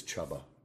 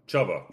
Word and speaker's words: Chuba: I got a definitive pronunciation, and it is Chuba. – Chuba.